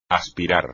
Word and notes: aspirar: espagnol

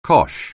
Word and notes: cosh: anglais

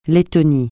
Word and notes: Lettonie: francais

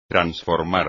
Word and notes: transformar: espagnol

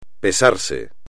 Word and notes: pesarse: Spanish

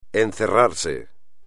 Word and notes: encerrarse: espagnol

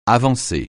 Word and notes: avancer: francais

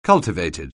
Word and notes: cultivated: anglais

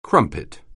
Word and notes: crumpet: anglais

